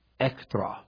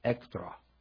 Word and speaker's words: echthra: In Ephesians 2:15, the passage I read at the top, the word enmity is the Greek noun ektra, that's spelled E-C-H-T-H-R-A, ektra. The word ektra is the feminine form of the Greek adjective ekthros, which is E-C-H-T-H-R-O-S, which means to hate or to be hostile to.